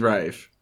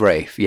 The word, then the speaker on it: Ralph: His name's Rafe. – His name is Rafe, yeah, but it's spelt Ralph.